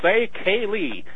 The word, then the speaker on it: Ceili: I like how the first album out of the gate, you taught people how to pronounce the name properly. – We tried. – It's called Say Kaylee.